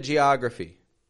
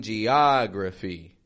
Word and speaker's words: geography: And notice how that ah in geography stretches out, right? In the same way as these other ahs. Different spelling. This one's an O. It's a short O. Geography. Gia. Just like thaw, caught, cause. So some geography.